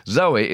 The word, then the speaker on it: Zowie: According to Nicholas Pegg's The Complete David Bowie, Zoe is pronounced like the girl's name, Zoe, intended as a masculine version of the Greek word for life.